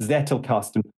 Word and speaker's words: Zettelkasten: Zettelkasten.